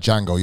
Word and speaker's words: Django: You know, it's like Django, you know, like the J is silent or like D silent, one of them, you know, the A is silent in planting.